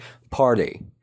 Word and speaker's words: party: تلفظ پارتی در انگلیسی آمریکایی به صورت party هستش. یعنی تی رو تقریبا مثل دی تلفظش میکنیم.